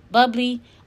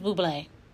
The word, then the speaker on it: Bublé: Is Michael Bublé? Bubbly? I think it's Bublé.